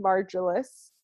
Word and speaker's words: Margolis: Her name is Anne Margolis. Actually, I didn't even ask you how to say your last. Margolis. – Margolis. I had a feeling. – That's right. It's always, many people mispronounce.